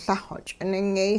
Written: Lah-xw ch'iningyay

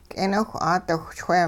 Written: k'eeniwh-'aadiwhchwee

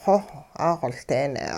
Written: xoh 'axoLdeene'

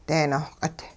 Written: dee-nohq'it

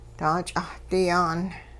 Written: daach'ahdi-yaan

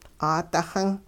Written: 'aadixin

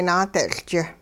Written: naade'tL'ch

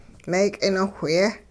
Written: mee'kinowhye

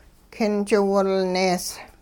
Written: king chiwilnees

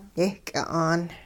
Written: yehky'a'aan

